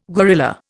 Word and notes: guerrilla: Indian

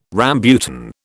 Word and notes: rambutan: British English

